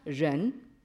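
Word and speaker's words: ren (仁): qui est donc le mot que l'on prononce en chinois contemporain, ren, transcrit R-E-N.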